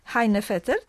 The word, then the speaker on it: Heinefetter: Pani Heinefetter, tak się wymawia jej nazwisko, czy? – Tak.